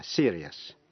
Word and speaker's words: serious: Còn nếu trước những tiếp vĩ ngữ I-O-U-S không phải là phụ âm C, T hay X, phát âm thành hai âm tiết là yes. Thí dụ, serious, đọc là serious.